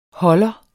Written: holder

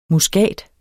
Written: muskat

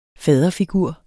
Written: faderfigur

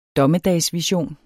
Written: DOMMEDAGSVISION

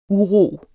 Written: uro

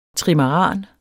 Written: trimaran

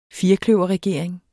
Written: firkløverregering

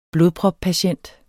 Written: blodproppatient

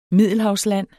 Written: middelhavsland

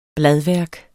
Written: bladværk

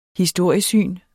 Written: historiesyn

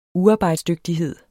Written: uarbejdsdygtighed

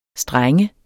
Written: streng

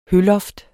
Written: høloft

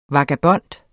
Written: vagabond

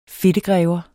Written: fedtegrever

